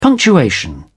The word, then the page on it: punctuation: British English